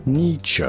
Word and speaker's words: Nietzsche: was written by Friedrich Nietzsche, or Nietzsche, or Nietzsche. – Now, his name in German would normally be pronounced Friedrich Nietzsche.